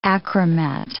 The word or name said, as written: achromat